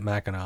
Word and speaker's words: Mackinac: Like, it looks like Mackinac, but everybody pronounces it Mackinac.